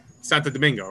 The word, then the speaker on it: Santo Domingo: Santo Domingo, right? There we go. That's how you say it.